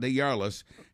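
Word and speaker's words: DesJarlais: But Republican Congressman Scott Day, and stick with me for the pronunciation of his name, it's French, I believe, Scott DeYarlis, has now admitted to having 6 extramarital affairs while helping his wife through 2 abortions.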